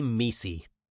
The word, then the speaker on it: MECE: Feel free to be messy. To structure your thinking when solving business problems, or anything for that matter, you must be complete while avoiding confusion and overlap. M-E-C-E, pronounced messy, stands for mutually exclusive, collectively exhaustive, and it is a sine qua non of the problem-solving process at McKinsey.